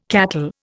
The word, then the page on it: cattle: Indian